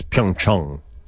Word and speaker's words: Pyeongchang: It's Pyeongchang. It's A-N-G ending, but it's O-N-G pronunciation. – Well, what's the O-N-G pronunciation then? – Mm.